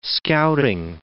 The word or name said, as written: scouting